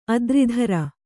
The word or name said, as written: ಅತಿರಾತ್ರ